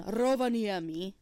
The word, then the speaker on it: Rovaniemi: I was I was in uh, the Arctic Circle in Rovaniemi. That's how Santa Claus taught me how to say it.